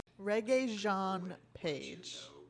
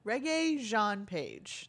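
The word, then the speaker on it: Regé-Jean Page: I did do a Google search of how to pronounce his name. Regey Jean Page. Regey Jean Page. There you go.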